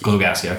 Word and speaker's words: Glogowski: It's Coach Glogowski. – Glogowski, okay.